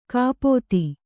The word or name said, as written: ಕಪಿತೆ